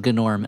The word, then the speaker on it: Gnorm: That somebody on Twitter was sure to correct me that the character does pronounce his name Gnorm, and I was like, look, I don't know.